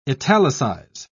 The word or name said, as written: italicize